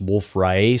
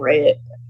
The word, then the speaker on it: Wolf-Rayet: i'm glad that you said that correctly i i did an interview recently where i just say, kept saying ray it and then after the interview i was like that's not how you say that guy's name it's riot